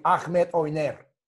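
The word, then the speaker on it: Ahmet Öner: Look, Avenue Yildirim is managed by Ahmet Oner, you call it. Or if to pronounce properly, Ahmet Oner.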